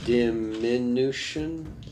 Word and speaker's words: diminution: Diminution. How do you pronounce that? – What? – D-I-M-I-N-U-T-I-O-N. – Diminutive? – T-I-O-N, not T-I-V-E. – Diminution? – Is it diminution? Because there's no A in it.